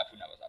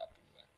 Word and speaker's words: Abu Nuwas: Nama cara ejaan ejaan Baghdad Abu Nawas bukan Abu Nawas Abu Nuwas.